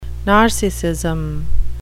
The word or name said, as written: narcissism